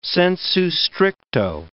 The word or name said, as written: sensu stricto